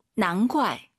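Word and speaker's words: 难怪: 자, 발음하실 때 선생님 이 난怪 우리가 이제 GUAI 사성이니까 怪 쌍격으로 많이 발음해요. 난怪가 예뻐요. 난怪가 예뻐요. – 다 예뻐요. – 다 예뻐요. 근데 쌍기역으로 난과이 이렇게 발음하는 중국인도 있죠. 근데 우리 선생님은 난과이 기역 느낌으로 발음을 했다는 걸 참고하시면 좋을 것 같습니다.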